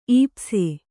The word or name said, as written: ಈಪ್ಸೆ